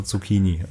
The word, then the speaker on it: Zucchini: Und dann macht die korrekte Aussprache Zucchini.